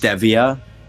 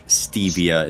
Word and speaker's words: Stevia: There's two different pronunciations. One's British, one's American. – Yeah. Of course. – Stevia is British. Stevia is American.